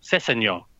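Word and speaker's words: Sessegnon: write it down, break it into syllables. Start off with the last syllable, so like nyon. Then you add the second syllable, senyon. And then you add the first syllable, sesenyon.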